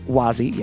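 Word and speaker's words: Wah Z: Gross, spiritual name, Dap Ren, succeeded him until October 22nd, 1981, when Klimp, spiritual name, Wazi, sounds like a rapper, pronounced Wazi, yeah, became the spiritual leader.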